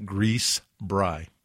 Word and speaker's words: Grießbrei: Having been brought up in a German home, one of the meals we often enjoyed as children was a dish called Grießbrei. It's pronounced just like that, Grießbrei.